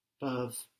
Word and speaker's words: peuvent: This is spelt P-E-U-V-E-N-T. E-N-T on the end of verbs in French isn't pronounced, but it is making us hear that V before it, no? P-E-U-V-E-N-T, peuvent.